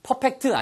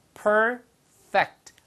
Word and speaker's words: perfect: 발음은 perfect 아니고요. perfect. 위치아가 아랫입술에 가서 붙는 거예요. perfect. 됐습니다.